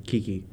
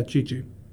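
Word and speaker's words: Qiqi: With her and Diona and Kiki. – Yeah, Chi-Chi. – Yeah. – Yeah, because it's a Chinese name, you pronounce the Q as a ch sound.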